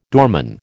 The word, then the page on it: doorman: American English